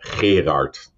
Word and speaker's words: Gerard: It's actually Gerard. – Oh my God. – No. Gerard. Yeah, but I won't do that to you. And I think Levan actually has a very nice pronunciation of my name.